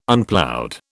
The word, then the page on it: Unploughed: British English